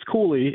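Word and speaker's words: Cuylle: Well, so to speak about – it's Cooley is how he pronounced it.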